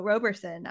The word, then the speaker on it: Roberson: And I'm sorry, I mispronounced your last name in the intro, Roberson.